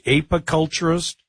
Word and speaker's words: apiculturist: Is it apiculturist? – Yes, the apiculturist part of it, right? – Is the honeybee, a honeybee specialist.